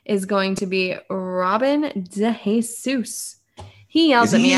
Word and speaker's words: DeJesus: It's Robin DeJesus. DeJesus.